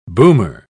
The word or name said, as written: boomer